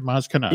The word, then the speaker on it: Maz Kanata: Am I saying that right? Maz Kanata. – Yeah, Maz Kanata. That's right.